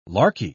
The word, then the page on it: larky: British English